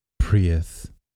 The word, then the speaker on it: Prius: You gotta say that correctly. Prius.